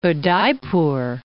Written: Udaipur

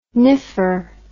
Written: niffer

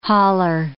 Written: hauler

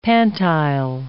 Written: pantile

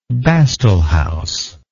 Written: bastle house

